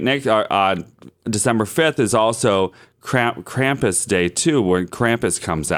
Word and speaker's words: Krampus: Krampus. – But it's spelled K-R-A-M-P-U-S.